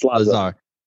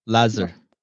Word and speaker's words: Lazar: It's Lazar. – Lazar. – You can say it. Both is correct.